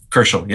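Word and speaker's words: Kerschl: Kerschel, yeah.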